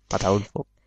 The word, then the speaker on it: Ataulfo: Ataulfo, con acento en la U. – Ataulfo.